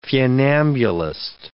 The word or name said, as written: funambulist